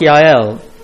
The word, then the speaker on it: Jael: Looks like Jael, but it's pronounced Yael.